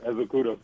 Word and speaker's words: Ezeakudo: Yep, Ezeakudo.